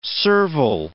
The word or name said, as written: servile